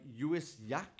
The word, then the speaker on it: Lluís Llach: Right, we're going to have a song by Lluís Llach. – Correct. – Which is spelled with double L's. It's not L, don't get excited, it's Y.